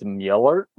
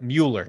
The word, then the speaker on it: Müller: I cannot say it. It's Mueller. – There we go. That was close. Yeah. – Do you see my face? Mueller. – You don't have to. – Do have to. – We'll do it like Ferris Bueller, but Mueller.